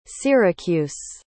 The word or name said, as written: syracuse